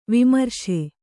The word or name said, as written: ವಿಮರ್ಶೆ